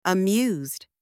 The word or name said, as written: amused